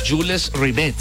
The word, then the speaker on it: Jules Rimet: La Jules Rimet, se dice así, la famosa copa de Jules.